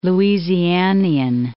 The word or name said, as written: Louisianian